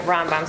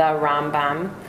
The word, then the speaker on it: Rambam: So in case you've ever heard of Nachmanides, and that's called the Ramban, so the way that you can hear the difference is Rambam has the accent on the first part of his name, and the Ramban has the accent on the second part of his name. So because they sound so similar, the accents are different, so you'll be able to recognize it when you hear it.